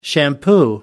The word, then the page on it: shampoo: American English